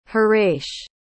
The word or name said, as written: hiresh